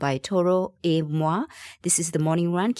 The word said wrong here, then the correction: Toro y Moi: BFM 89.9, and that was Still Sound by Toro y Moi, whose name I butchered just 2-3 minutes ago.